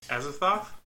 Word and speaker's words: Azathoth: I'm blaming Cthulhu and Azoth. – Azathoth? – Azathoth, oh my gosh.